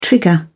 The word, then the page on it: trigger: British English